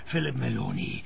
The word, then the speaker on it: Philip Maloney: Hocherfreut, mein Name ist nach wie vor Philip Meloni und wird auch so ausgesprochen.